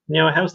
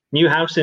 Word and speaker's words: Neuhaus: I mean, we mentioned just before we came, went through a number of players, and Florian Neuhaus, have you ever pronounced it? Neuhaus, that's the one. Neuhaus in German, that is.